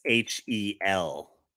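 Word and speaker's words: Fishel: I thought it was Fisher. Danielle Fisher. – Fischel. – Oh, Fischel. Okay, yeah, I don't know. – So I guess that's how you say it. Fischel.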